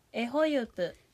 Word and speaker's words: エホユプ: はい、エホユプ、エホユプ。ピリカ、ピリカです。これもね、ホユプの U のどこにアクセントあったのが、エがつくことでホの上にアクセントが来ちゃう、エホユプになっちゃうってことです。